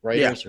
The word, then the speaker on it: Reuters: I think that's how you pronounce it Reuters yeah.